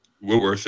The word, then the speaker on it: Whitworth: The guy that was playing left tackle for the Rams, Whitman? – Whitworth, yeah. Yeah. Whitworth? Is that how you say his name? – Yeah, Andrew yeah. Whitworth, yeah.